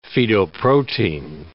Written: fetoprotein

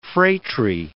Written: phratry

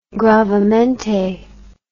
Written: gravemente

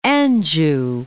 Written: Anjou

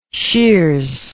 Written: sheers

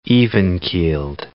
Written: even-keeled

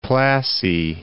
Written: Plassey

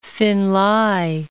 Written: Finlay

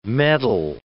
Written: medal

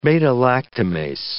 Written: beta-lactamase

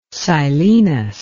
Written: seilenos

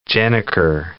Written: gennaker

